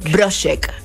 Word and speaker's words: Brochek: ¿Cómo se pronuncia? – Brochek. – No, ¿de dónde es? – Es checo. – Checo, Brochek. Igual hay como un tono para pronunciarlo, los apellidos checos.